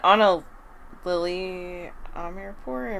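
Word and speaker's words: Ana Lily Amirpour: And Anna Lily Amirpour, am I saying her name right? – Yes, I think so.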